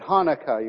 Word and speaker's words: Chanukah: The sea is silent, just like in Chanukah. The Jews celebrate Hanukkah, even though it's spelt Chanukah.